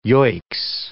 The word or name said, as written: yoicks